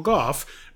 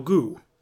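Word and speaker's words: Gough: His name is Michael Goff, but it's spelled like Huck, but it's spelled very similar to Derek's name. For the longest time, I thought it was Michael Goo.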